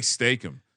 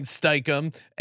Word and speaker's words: Steichen: No, you said it wrong. – I'm saying like Steichen. – Steichen. There's an E in there.